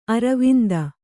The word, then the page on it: ಅರವಿಂದ: aravinda